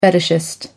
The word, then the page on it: fetishist: American English